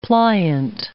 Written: pliant

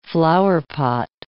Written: flowerpot

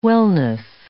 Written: wellness

